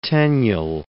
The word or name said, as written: Tenniel